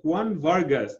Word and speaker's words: Juan Vargas: And today we have Juan Vargas.